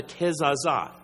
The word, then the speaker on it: Kezazah: And it's basically, it says here, he was a candidate for what's called a Kezazah. K-E-Z-A-Z-A-H.